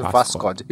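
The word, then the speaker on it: Vaskod (Восход): Eu não tô aqui falando errado, é, o correto russo é Vaskod.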